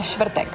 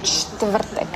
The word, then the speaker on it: čtvrtek: Por ejemplo, no hace falta pronunciar todas las consonantes. Por ejemplo, Štvrtek o Štyři. Por ejemplo, conozco muchos checos que dicen Štvrtek. En vez de Čtvrtek.